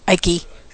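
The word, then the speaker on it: Icke: Okay, I mispronounced the name. It's Ike. Yeah, not David Ike. My bad. – Ike, yeah.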